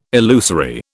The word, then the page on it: illusory: British English